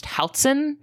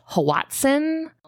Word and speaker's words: hoatzin: So my readings for the hoatzin, which that's how I'm pronouncing it. That is spelled, by the way, H O A T Z I N. Um, I've heard it pronounced Houtson. I've heard it pronounced Hawatson. – Do you know what language it's from? – Yeah, it comes from the Nahuatl language, the language spoken by people that lived there.